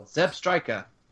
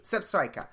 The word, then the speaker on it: Zebstrika: Um, I can never pronounce Sabreska's name correctly, so Zebstrika. – Thank you, Zebstrika.